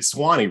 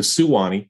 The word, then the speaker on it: Suwanee: So he found another one, and he dropped a syllable and made it Swanee River instead of Suwanee, as we all know it to be pronounced.